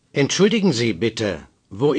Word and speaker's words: Entschuldigen: Strong stress on the U. Say. – Entschuldigen.